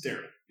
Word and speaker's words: Daryl: Yeah, So Daryl or Darrell? it's Daryl. Because Daryl rhymes with barrel, Daryl. and I like to call him Daryl the Barrel.